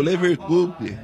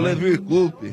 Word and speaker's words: Leverkusen: O Leverkusen. – Você da pronúncia. – É o Leverkusen. – Leverkusen.